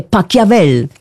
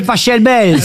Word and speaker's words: Pachelbel: Cannelloni, c'est Pachelbel. – S'il est allemand, ça se dit Pachelbel. – Exactement, ça pourrait se dire éventuellement Pachelbel, mais on dit en tout cas, ici en France, le canon de Pachelbel.